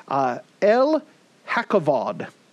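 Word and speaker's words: El Hakkavod: With this, we see God's name here, El Hakavod. El Hakavod, for those spelling, it's E-L space H-A-K-K-A-V-O-D.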